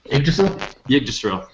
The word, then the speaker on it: Yggdrasil: Was it Undead Viking, or was it how do you say it? – Yggdrasil. – Yep.